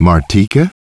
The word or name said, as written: Martika